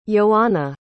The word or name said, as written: ioanna